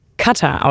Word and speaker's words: Katar: Ja, wer genau hingehört hat und Experte in kommenden Gesprächen über die WM sein will, es wird Qatar ausgesprochen, also mit der Betonung auf die erste Silbe.